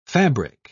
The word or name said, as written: fabric